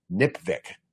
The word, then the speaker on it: NPVIC: I introduce a way to pronounce it, which I've never heard anywhere else, NIPVIC, which rhymes with lipstick.